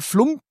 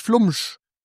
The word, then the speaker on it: Flumsch: Zwischen den M und dem STH ist nichts mehr. Also da ist kein Flump oder sonst irgendwas, sondern wirklich Flumsch.